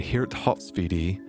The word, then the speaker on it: Geert Hofstede: In the 20th century, a Dutch social psychologist named Geert Hofstede, that's G-E-E-R-T, H-O-F-S-T-E-D-E, I know that's a tricky name for we Americans to try to pronounce in his proper Dutch pronunciation, Geert Hofstede, produced groundbreaking research in the field of cross-cultural studies.